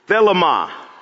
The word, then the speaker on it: Thelema: Thelema. That's the Greek pronunciation of his will.